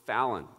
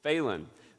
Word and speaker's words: Phelan: And so long as I've known Lainey, I've always been sure her name was pronounced Fallon, and always pronounced her name Fallon. Then on the video, she pronounced it's Phelan.